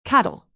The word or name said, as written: cattle